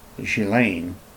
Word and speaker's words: Ghislaine: It's pronounced Ghislaine, even though it's spelled Ghislaine.